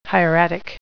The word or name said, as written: hieratic